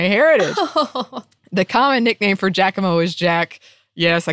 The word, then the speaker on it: Giacomo: With regards to mispronunciation, I can't remember the podcast, but I believe Annie mentioned Giacomo, but pronounced it Giacomo.